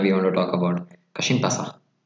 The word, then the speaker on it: Kasımpaşa: Moving on to the next pronunciation is another club from Istanbul, Kasımpaşa. – Another team, another behind them, there is a big company. – Did I say it right firstly? – Kasımpaşa.